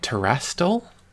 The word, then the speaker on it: Terastal: And this one is, I'll make sure I'm saying this right, Terastal.